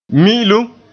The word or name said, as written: mi'ilum